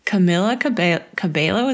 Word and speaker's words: Camila Cabello: It is starring Camila Cabello.